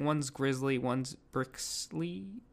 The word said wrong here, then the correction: Bisley: I said that wrong, and one's, insert name. – Condifiction. Also, it's pronounced Bisley.